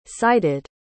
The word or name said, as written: sited